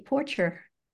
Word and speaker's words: Porcher: And now we'd just like to please welcome Nikki Porcher. – This is always the awkward part because it's pronounced Porche.